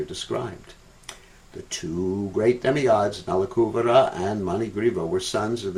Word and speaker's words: Nalakuvara: Many devotees pronounce it as Nalakuvara. It's not Nalakuvara, it's Nalakuvara. Nalakuvara.